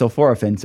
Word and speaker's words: sulforaphane: and all these years I've been saying it's sulforaphane, the first thing I learned today is, no, the academic science guys say it's sulforaphane.